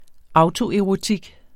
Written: autoerotik

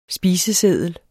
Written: spiseseddel

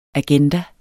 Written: agenda

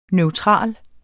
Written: neutral

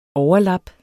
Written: overlap

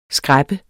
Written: skræppeblad

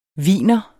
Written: wiener

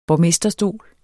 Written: borgmesterstol

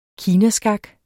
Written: kinaskak

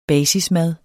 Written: basismad

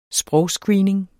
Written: sprogscreening

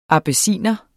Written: abyssinier

